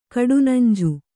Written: ಕಡುನಂಜು

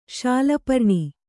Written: ಶಾಲಪರ್ಣಿ

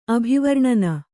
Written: ಅಭಿವರ್ಣನ

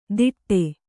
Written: ತಿಟ್ಟೆ

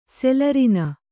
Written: ಸೆಲರಿನ

